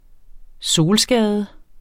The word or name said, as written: solskade